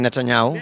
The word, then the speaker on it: Netanyahu: Benjamin Netanyahu. – Netanyahu.